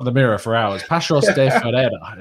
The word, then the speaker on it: Paços de Ferreira: Tell me, Pablo, that was probably a horrific pronunciation, but it's Paços, is it? Because you pronounce the C with the little accent on the bottom with like a hard sound. It's not like a shell.